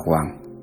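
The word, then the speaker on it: Quang (དབང): Đáp: lễ quán đảnh tiếng Phạn là Abhisekha, tạng ngữ là Đà Bang, đọc là Quang.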